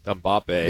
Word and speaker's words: Mbappe: Mbappe. – Yeah, Mbappe, yeah, you got it. – Mbappe. – Yeah. That was pretty good. – Hat-trick. Yeah, that right? – I mean, Kylian Mbappe.